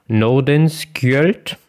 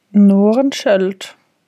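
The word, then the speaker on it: Nordenskiöld: Norenskjöld, würde ich sagen. – Ah, Norenskjöld. Also man schreibt es S-K-I-Ö-L-D. – Ich glaube, das sagt man Sch Schöld, aber don't know. Adolf Erik Nordenskjöld.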